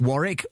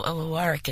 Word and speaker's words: Warwick: It's W-A-R-R-I-C-K. A label copy misprint gave me a W instead of an R. And in the States, they pronounce all the syllables, so I became Warwick. And here I'm still Warwick, so I answered to both.